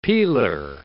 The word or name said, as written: peeler